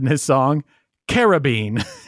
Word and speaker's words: Caribbean: How do you say it? I say Caribbean. – I do too. – You could also say Caribbean.